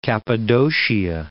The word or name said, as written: Cappadocia